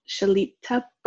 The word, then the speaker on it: Shelita: Shelita Burke, although my artist name is just Shelita.